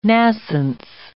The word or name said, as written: nascence